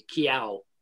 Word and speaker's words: Kiao: It's pronounced like the cat sound meow with a K, Kiao.